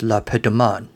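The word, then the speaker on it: Le Pétomane: Actually, the French pronunciation is La Petemaine.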